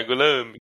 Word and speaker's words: Gorlami: Gorlami, é, Gorlami. Lo pronuncio corretamente? Uh, eh, sim, uh, eh, correto.